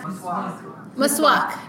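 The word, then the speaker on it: Maswak (مسواک): I think the word for it is makwas. Is that right? Am I saying it right? I'm looking at the audience, yeah? Maswak. – Yeah, maswak. In Persian we say maswak.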